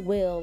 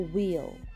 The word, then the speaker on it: weal: I hope I say it right, wheel. It's spelled W-E-A-L. Not will, like W-I-L. Your wheel, W-E-A-L.